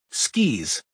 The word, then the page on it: skis: American English